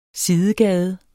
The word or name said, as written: sidegade